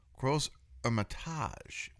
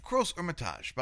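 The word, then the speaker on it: Crozes-Hermitage: And Gros Hermitage, by the way, Gros Hermitage is spelled C-R-O-Z-E-S. The S is silent, then H-E-R-M-I-T-A-G-E. The H is silent. So S and H are silent. But very classic French pronunciation.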